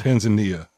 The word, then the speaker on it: Tanzania: Tanzania.